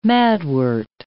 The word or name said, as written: madwort